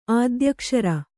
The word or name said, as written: ಆದ್ಯಕ್ಷರ